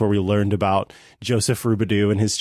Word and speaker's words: Robidoux: It's spelled R-O-B-I-D-O-U-X, Rubidoux.